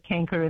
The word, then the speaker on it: canker: Yes, well, paint any of your citrus trees that should be painted as a prophylactic to prevent canker in the graft. – And it's canker, you call it, yeah, okay. C, yes. How did you spell that? C, is it? C-A-N? – That's K-E-R, yes.